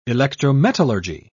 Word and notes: electrometallurgy: British English

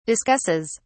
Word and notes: discusses: British English

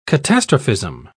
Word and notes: catastrophism: British English

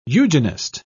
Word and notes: eugenist: British English

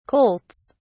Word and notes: colts: British English

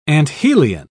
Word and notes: anthelion: British English

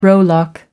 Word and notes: rowlock: American English

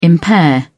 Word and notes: impair: British English

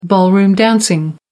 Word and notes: ballroom dancing: American English

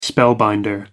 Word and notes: spellbinder: American English

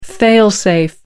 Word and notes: fail-safe: American English

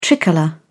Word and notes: tricolour: British English

